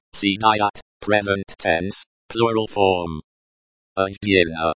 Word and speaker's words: отдельно: Отдельно. Pronounced like отдельно, stress on the second syllable.